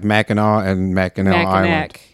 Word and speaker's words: Mackinac: But that's another one that I was always like confused why one is C and one is W, and yet they're both pronounced Mackinac.